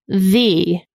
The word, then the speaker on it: ye: Ye, as in ye old Sephoris, is actually pronounced the.